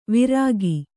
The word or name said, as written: ವಿರಾಗಿ